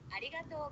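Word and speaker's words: arigatou (ありがとう): In Japanese, thank you is arigatou gozaimasu.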